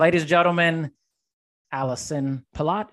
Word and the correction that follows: Pelot: Is it Palat? – uh, it's close. Pillow. The Pillow. T is silent.